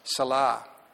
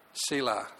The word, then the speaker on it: Selah: There's a Hebrew word used 71 times in the Psalms, Salah. Is that how you pronounce it? Selah. Thank you.